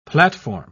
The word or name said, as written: platform